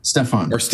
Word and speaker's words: Stefan: It is such a fun film about such an interesting gentleman, Stephen Pokorny. – Stefan. – I'm sorry. I actually have it spelled right in front of me and I said it wrong anyway.